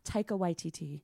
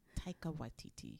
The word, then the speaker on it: Taika Waititi: How do you say his name? – Taika Waititi. – Taika Waititi. – Waititi. Yeah, you say so fine.